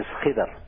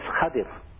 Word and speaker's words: Khidr: Some pronounce it as Khidr. Some pronounce it as Khadir.